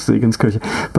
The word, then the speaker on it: Segenskirche: And the church is Segenskirche. But you know, this is just like, yeah.